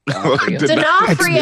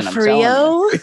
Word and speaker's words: D'Onofrio: D'Onofrio. – Yeah, you don't. Yeah, I'm bad at pronouncing names, but I got the information. – D'Onofrio. – D'Onofrio. That's exactly how it's spelled.